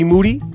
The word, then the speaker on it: Moody: The man name is G Moody. His last name rhymes with duty.